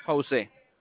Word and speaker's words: Jose: Jose.